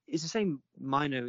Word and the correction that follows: Mainoo: is still... Is it Mimo? Mino? How do you say his name? – I don't know who you're talking about. – The guy, Man United kid. You know, everyone was saying... – Bobby Mainoo. – Oh, Mainoo.